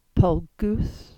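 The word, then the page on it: Pollgoodh: Cornish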